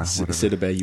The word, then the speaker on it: Sidibe: Yeah, Sidibe, you were so close.